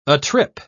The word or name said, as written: atrip